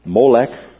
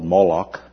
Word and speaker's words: Molech: He spoke of the Jews' idolatrous worship of the pagan deities Molech, or sometimes pronounced and spelled Moloch, and Rampha.